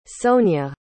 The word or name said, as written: sonja